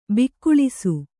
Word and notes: ಬಗುಳಿಸು: baguḷisu

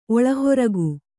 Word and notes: ಒಳಹೊರಗು: kannada

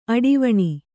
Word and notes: ಅಡಿವಣಿ: aḍivaṇi

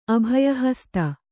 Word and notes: ಅಭಯಹಸ್ತ: abhayahasta

